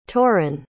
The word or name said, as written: tauren